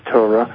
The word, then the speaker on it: Torah: On when you read the Tarot, do you... – The Torah? Yes. – Oh, Torah. I'm sorry. I mispronounced it.